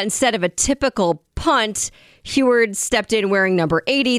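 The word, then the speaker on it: Huard: All right, so the Big Ten says USC should have been penalized after having reserve quarterback Sam Heward, is that how you say his name? – Yes. Yep.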